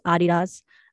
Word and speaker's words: Adidas: No, it's Hoka, H-O-K-A. It's like an athletic wear brand, kind of like Nike, Adidas, or how it should be pronounced, Adidas.